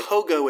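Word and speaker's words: haut goût: It's H-A-U-T. – Okay. – G-A-U-T. – Okay. – HOGO is how it's pronounced. It means high flavor.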